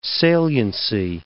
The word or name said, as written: saliency